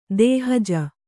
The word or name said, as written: ದೇಹಜ